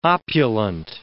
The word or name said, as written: opulent